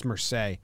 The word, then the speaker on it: Marseilles: It's just Mersey. But it's spelled like it's Mersey's or I don't know. It's a different language.